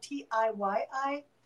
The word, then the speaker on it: Tiyi: It's spelled T-I-Y-I, and it's pronounced T-I, like the letter T and the letter I.